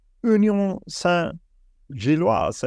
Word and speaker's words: Union Saint-Gilloise: 5 mål hittills för Union Saint-Gilloise. Är det så man uttalar det? – Ja, bra uttal.